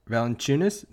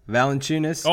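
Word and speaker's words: Valančiūnas: Oh, is Wes and Salah for Valanciunas? Did I say it right? – You did say it right this time. – Valanciunas.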